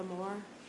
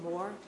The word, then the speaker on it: Moore: It's Martha Moore, M-E-R-T-H-A. Last name, Moore, M-O-O-R-E.